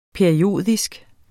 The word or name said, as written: periodisk